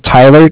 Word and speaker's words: Tyler: My name is Tyler, T-Y-L-E-R, and I'm calling with Cardiff. It's spelled C-A-R-D-I-F-F, as in Frank. And D-I-F-F? – Correct.